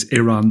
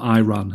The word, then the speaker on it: Iran: Did you see that press conference the other day where an Iranian journalist said to Tyler Adams that you're pronouncing the name of our country wrong? He said it's Iran, not Iran.